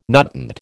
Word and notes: nutant: American English

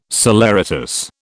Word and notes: saleratus: British English

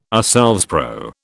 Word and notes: ourselves: British English

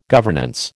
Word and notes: governance: American English

